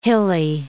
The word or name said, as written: hilly